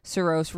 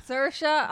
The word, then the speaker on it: Saoirse: Other nominees for Best Actress were Sally Hawkins in The Shape of Water, Margot Robbie in I, Tonya, Saoirse Ronan for Lady Bird. – Saoirse.